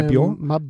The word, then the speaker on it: Mabior: Four goals to Sydney Stack, three goals to Mabior. Is it? Have I got the pronunciation right? Yeah, Mabior? Chole, yes. Three.